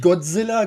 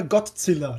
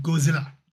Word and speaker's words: Godzilla: Nee, nicht Godzilla, Godzilla. Das ist ein Unterschied. Nein. Müssen wir ja schon richtig aussprechen. Godzilla.